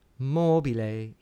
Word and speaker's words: mobile: So think about la donna mobile, mobile. The accent is on the third last syllable.